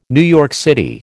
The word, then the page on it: NYC: American English